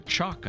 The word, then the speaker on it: CHOC: My guest today is Joy Silver, who is the Regional Director for Southern California of the Community Housing Opportunities Corporation, CHOC, or CHOC, I believe you pronounce it. – Yes.